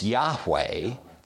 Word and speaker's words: Yahweh: So the speculation is that it's not Jehovah, but it's Yahweh, that's how it would have been pronounced.